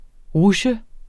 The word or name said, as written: rutsje